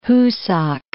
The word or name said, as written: Husak